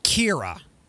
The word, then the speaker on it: Ciara: A-O-I-F-E. – A-O-I-F-E. – The name Sierra, like the singer, Sierra. C-I-A-R-A is pronounced Kira. – That's gonna get messed up. – Roisin, R-O-I-S-I-N.